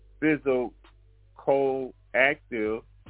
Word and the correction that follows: psychoactive: That one is psychoactive. – Okay, the P is silent. The P is silent. Okay, psychoactive drug that is the active ingredient in drinks such as beer, wine, and distilled spirits, hard liquor.